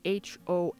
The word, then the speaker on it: hostia: But it's used to mean shit or holy shit, and it's spelled H-O-S-T-I-A, and it's a bit of Spanglish, so it should be easy for some of you guys to remember as well. Hostia, host-ia, holy shit. The next word is gilipollas, spelled G-I-L-I-P-O-L-L-A-S.